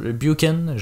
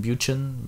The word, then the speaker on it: Buchan: Buchan, je sais pas si je le prononce, B-U-C-H-A-N, Buchan, Buchan probablement.